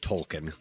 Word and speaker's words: Tolkien: And I believe they say in the movie it's pronounced Tolkien. – No, okay. – Not Tolkien. – A lot of the snooty people say Tolkien.